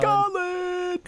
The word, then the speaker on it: Khaled: There's like DJ Khaled has his tune out now, or Khaled, whatever the fuck his name is. How do you pronounce it? Do you know? – DJ Khaled. – Khaled.